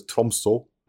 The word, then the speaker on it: Tromsø: So she went as far north as Tromsø. – Great pronunciation. – Good accent. – Yeah. – Tromsø.